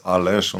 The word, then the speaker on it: Aleš: Ich habe nochmal nachgelesen, also dieses Zeichen auf dem S bei Aleš, das ist ein Hatschek, ein Winkelakzent. Und der führt eben dazu, dass das wie Sch ausgesprochen wird. – Ja, das ist Sch, so, das ist Aleš.